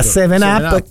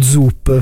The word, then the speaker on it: 7-Up: che era 7-Up, che e noi da ragazzi la prime volte, scusi, mi dà uno Zup, perché sembrava con la Z, no? E invece era un 7, e noi all'italiana...